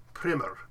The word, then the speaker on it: primer: The Latin stress would be on the second syllable, primarius. And with that, in English, a lot of times when that seemed to have happened, the long E part became shortened to I, to a schwa sound. So it became primer.